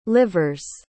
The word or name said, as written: livers